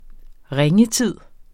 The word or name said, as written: ringetid